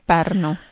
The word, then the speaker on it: Pärnu: Pärnu.